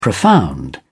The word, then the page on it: profound: British English